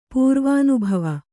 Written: ಪೂರ್ವಾನುಭವ

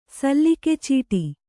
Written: ಸಲ್ಲಿಕೆಚೀಟಿ

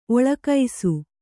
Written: ಓಲಗಿಸು

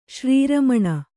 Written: ಶ್ರೀರಮಣ

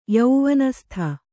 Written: ಯೌವನಸ್ಥ

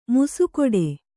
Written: ಮೀಸೆಗಡಿ

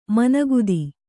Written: ಮನೋಗತ

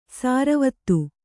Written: ಸರವತ್ತು

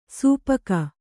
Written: ಸಪ್ಪಗೆ

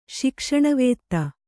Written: ಶಿಕ್ಷಣವೇತ್ತ

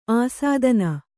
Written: ಆಸ್ಥಾನ

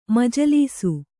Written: ಮಜಲೀಸು

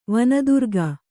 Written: ವನದುರ್ಗೆ

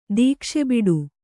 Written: ದೀಕ್ಷೆಬಿಡು

